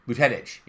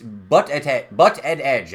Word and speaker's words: Buttigieg: Buttigieg, yeah. – Buttigieg? – It's Butt-Ed-Edge. That's how I keep thinking about it.